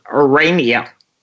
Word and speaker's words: Aurania: Do I pronounce that right? – It's Aurania. – Aurania, of course. The A-U threw me. Aurania. Aurania Resources. Traded in Toronto, A-R-U is the symbol, and you can buy it in the U.S.